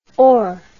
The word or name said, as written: hors